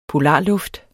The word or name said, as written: polarluft